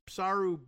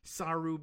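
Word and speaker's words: Psarou: While her staff allegedly suffers from high turnover, Guilfoyle was spotted sipping bubbly at Namos, an exclusive club on Mykonos' Psaru Beach in July. P-S-A-R-O-U. I don't know whether you're pronouncing the P in there or not. Psaru Beach.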